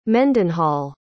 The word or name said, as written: Mendenhall